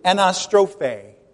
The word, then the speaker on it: anastrophe: The word is anastrophe. Actually, accent on the last syllable. Anastrophe. And not apostrophe, but anastrophe.